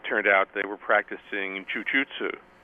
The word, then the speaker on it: jujutsu: Japanese, the right pronunciation, incidentally. – Was it Japanese jujutsu or was it Brazilian? – Jujutsu, yeah, just traditional Japanese jujutsu. – Mm-hmm. – That. – You pronounce it jujutsu.